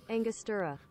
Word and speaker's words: Angostura: And I went to Angostura.